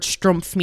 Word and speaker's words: schtroumpf: Strumpf. – Okay. – It is pronounced like the German word Strumpf, meaning sock.